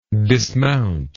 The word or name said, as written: dismountable